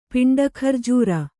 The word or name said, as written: ಪಿಂಡಖರ್ಜೂರ